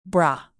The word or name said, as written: bra